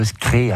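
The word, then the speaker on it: skreï: Oui, ouais, je voulais vous sensibiliser sur le skré. Alors, le skré, c'est un cabillaud. – Mais comment ça s'écrit le skré? – S-K-R-E-I.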